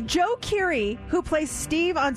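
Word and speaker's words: Djo: And the song is literally blowing up, but his singing like name when he posted his music online, it's D-J-O, Joe. But he says the D is silent.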